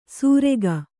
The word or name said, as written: ಸೂರೆಗ